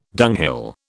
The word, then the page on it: dunghill: British English